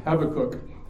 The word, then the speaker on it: Habakkuk: If you like to listen to Alistair Begg, and I do, he pronounces it Habakkuk.